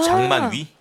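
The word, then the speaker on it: 장만 위 (張曼玉): 장만 위, 구슬옥자를 위 하고 발음하거든요. – 왜 바꾸래요? 그렇게. – 중국식 발음으로 중국식 하라는 발음. 거죠. – 중국식 발음으로 장만 위로 하라고요? – 네. 왜냐하면 장만까지는 똑같거든요. 네. 그런데 발음 앞에 걸 조심해야 되는 게 네. 짱 해버리면 더럽다는 뜻이에요. – 아. – 더러운 만옥이에요. – 아유 안 돼. – 그래서 짱. 양 발음을 해줘야 돼요.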